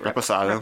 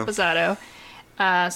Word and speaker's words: reposado: Reposado. – Reposado.